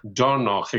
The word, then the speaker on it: Dornoch: And you're in Carnoustie, but this past weekend, you were in, now am I pronouncing this properly, Dornoch? – Dornoch, exactly.